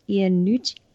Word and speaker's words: Iannucci: And Death of Stalin in like big 180 is a sort of political satire by Armando Iannucci. Is that how you say his name? Yes. – Yes.